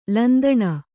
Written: ಲಂತಾನ

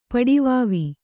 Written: ಪಡಿವಾವಿ

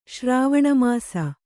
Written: ಶ್ರಾವಣಮಾಸ

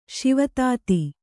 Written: ಶಿವತಾತಿ

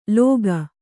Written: ಲೋಕ

